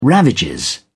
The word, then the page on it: ravages: British English